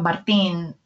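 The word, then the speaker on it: Martín: Because I grew up Martin, M-A-R-T-I-N, accent over the I.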